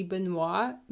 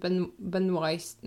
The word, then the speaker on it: Benoist: Anyway, I think there was a James Corden show where she said that like it's supposed to be Benoit, but like her family pronounced it Benoist. No, how did you say it? – Benoist. – Benoist. Her family pronounced it Benoist, and that's how it's supposed to be pronounced because that's like you know how the family pronounce it.